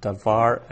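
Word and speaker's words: davar (דָּבָר): So the bait there, no dagesh, so it's a soft V, davar.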